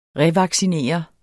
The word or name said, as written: revaccinere